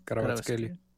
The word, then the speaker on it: Kvaratskhelia: Kvaratskhelia, ¿cómo se dice? – Sí, Kvaratskhelia.